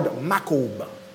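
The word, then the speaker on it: makob (מכאוב): Now, the word griefs there, the Hebrew word griefs there, happens to be the word makob. M-A-K-O-B. That's how it's pronounced. Makob.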